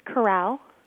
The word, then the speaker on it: corral: Say it again, please. – A corral. – Uh-huh. Okay. – Two syllables there.